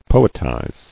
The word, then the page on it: Poetize: po·et·ize